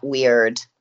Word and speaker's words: Wyrd: How do you say that word, WYRD? – Just exactly like that, WYRD. – WYRD. It is weird.